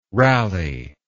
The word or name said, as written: rallye